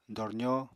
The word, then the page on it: dorzó: apatani